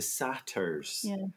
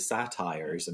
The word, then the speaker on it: satyr: So it was the satyrs, and I kept thinking it was the satires, and I'm going, no, it's actually a satyr.